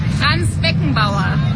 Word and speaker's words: Beckenbauer: Franz Beckenbauer.